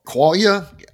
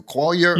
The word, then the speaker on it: qualia: Is this what would be called qualia? I think you pronounce it different than I do. Qualia? – Yeah. Qualia or qualia.